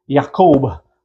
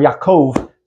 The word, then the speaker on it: Jacob: Okay, Genesis chapter 32, continuing the life of Jacob, Jacob, or Yaakov sometimes, as it's pronounced in Hebrew.